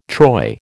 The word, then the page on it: troy: American English